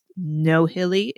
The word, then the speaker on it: Nohilly: and I think it's pronounced Nohilly. It's spelled that way N O H I L L Y.